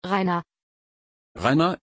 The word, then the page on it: rayna: German